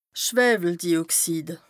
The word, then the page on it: Schwëweldioxid: Bas Rhin